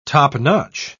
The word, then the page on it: topnotch: British English